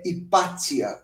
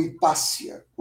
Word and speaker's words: Hipátia: A minha favorita, para citar um caso, é Hipátia, ou Hipácia, como também é pronunciado o nome dela foi considerada a primeira mulher matemática da história, no sentido de ser uma cientista dedicada a pensar e ensinar ciência.